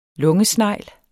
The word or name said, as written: Pulmonata